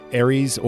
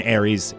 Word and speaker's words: Aerith: and Ares, well, it's spelled Aerith, in Ares, it depends on the Japanese translation or the American translation.